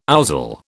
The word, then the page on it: ouzel: British English